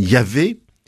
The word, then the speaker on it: Yahvé: On a essayé de les vocaliser dans nombre de Bibles par les sons E-A-E, ça donne Yahvé.